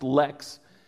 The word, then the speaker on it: Leax: Came across a wonderful quote from a poet named John Lex, L-E-A-X, it's pronounced Lex, and he's a marvelous American poet, and I love what he says here.